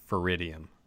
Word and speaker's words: feridium: I think it is pronounced feridium. If I yeah. – Feridium. – F e r i d i u m. Feridium.